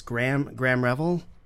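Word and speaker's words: Graeme Revell: So this is, I think it's Graham Revel. Is that how you say his name? Graham? – Yeah, I think Revel. That would be Graham. – It's spelled G-R-A-E-M-E, you know, whatever.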